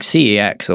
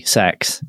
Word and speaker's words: CeX: Like CEX, or I should say it correctly, sex. – Yes. – Yes, that is how you say it.